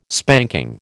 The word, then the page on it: spanking: American English